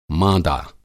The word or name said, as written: माँदा